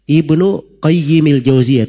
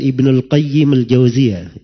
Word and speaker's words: Ibnu Qayyimil Jauziyah: Kalau mau disebut Al-Jauziyahnya dibacanya Ibnu Qayyimil Jauziyah, tidak pakai alif lam pada Al-Qayyimnya. Tidak pakai alif lam. Tapi dikatakan Ibnu Qayyimil Jauziyah. Kalau mau dipakai alif lam, dikatakan Ibnul Qayyim saya. Jangan dikatakan Ibnul qayyimil Jauziyah. Itu keliru ya cara cara bacanya, keliru.